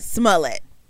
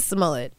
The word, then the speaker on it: Smollett: Smollett. – Jussie Smollett.